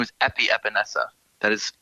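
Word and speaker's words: Epenesa: AJ Espensa. Correct me if I pronounce that wrong, because phonetics is my Achilles heel. But defensive end, second round, edge rusher, hit or miss for you. – It is Epinesa, actually.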